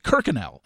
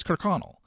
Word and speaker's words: Kirkconnell: and she pronounced it Kirkenell. And I'm like, that's not how you pronounce it. Not that I mean, I just the second I saw that last name, I was like, oh, that's Kirkconnell.